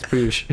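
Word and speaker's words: Piyush: His real name is like Priyush. – 15 syllables. Oh, yeah, it is Priyush. – Is it Priyush? – Is it Priyush? – Yeah, it's Priyush.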